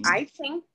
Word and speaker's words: I Ching: Well, it's a logical system that brings together principles of the Qing. – I Ching. – Is it I Ching? Oh, okay. I knew I'd say that wrong.